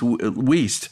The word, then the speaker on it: Wuest: Her name was Jackie Wiest, a detective, W-U-E-S-T, pronounced Wiest.